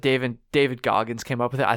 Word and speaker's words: David Goggins: David Goggins. – Yeah, David Goggins.